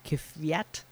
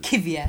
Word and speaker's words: Kvyat: Ninth, Kvyat. I'm not good at that pronunciation. Tenth, Kvyat. – Perez. 11th, I don't... Latvia. – What? Who are you? – It's L-A-T.